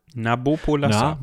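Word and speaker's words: Nabopolassar: Die hat sich nämlich Napolassa eingemeindet in seinem Napopolassa. Napopolassa. Napopolassa. Nee, Nap Nabopolassa.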